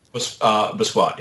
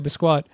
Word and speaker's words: Basquiat: And if you like Andy Warhol and that sort of period of history, I would recommend a film called, and I bet I'm mispronouncing this, Thrasher, Basquiat? – Basquiat, yeah. – Okay, Basquiat.